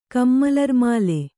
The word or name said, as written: ಕಮ್ಮಲರ್ಮಾಲೆ